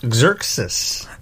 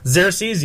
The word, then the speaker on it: Xerxes: Frank Miller's Xerxes. – Xerxes, yeah.